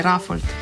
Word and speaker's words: Rafold: V uprizoritvi igrajo Aleksandar Jovanovski, Čarna Lampret, Jakob Podjavoršek, Lara Rajni, Marko Arfold, Nate Skravos, Neža Dvorščak, Peter Podgoršek, Jure Šimonka, Urban Brenčič, sem koga narobe izgovoril? – Ja, mislim, da je Rafold. – No. Marko Rafold, pardon.